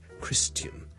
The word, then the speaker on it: Christian: 而基督徒 Christian 咧，我哋上次讲咗，亦都有一啲又系老一辈嘅人咧，可能会读成咧 Christian，嗰个发音好似 tian 咁，你当系好似人啦，跟住前面加好轻嘅 t 音 tian，OK，咁但系呢个发音咧系唔需要学嘅，OK，咁但系咧听到就唔好话错，OK，Christian question，譬如 I'd like to answer your question。